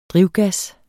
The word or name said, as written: drivgas